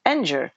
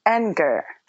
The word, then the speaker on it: anger: потому что в angel-то G, вот, и на этом плане возникают конфузы, но, ну, знаете, повторение мать учения, поэтому anger, а также begin и finger – все эти вещи произносятся как «г», как такая твердая русская «г» достаточно понятная и милая русскому уху.